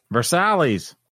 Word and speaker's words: Versailles: It's probably, you know, for Kentucky, it's probably pronounced Versailles. – Versailles. – Versailles.